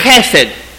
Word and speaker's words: chesed: Say it with me. Chesed. Okay, now if you're sitting in front, behind somebody, and you don't see something on their head right here, you didn't say it right. Try it again. Chesed. Yeah, it's one of those words where somebody's kind of sort of hoping you're not behind them.